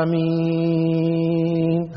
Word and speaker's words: آمين: أما كيف تلفظ فالأقرب والعلم عند الله أن تمد الهمزة حركتين فقط، ثم تمد اللي هو المد العارض للسكون 4 أو 6 حركات، يعني هكذا آمين هكذا.